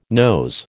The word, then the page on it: nose: American English